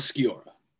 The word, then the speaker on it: Sciorra: Annabella Sciorra.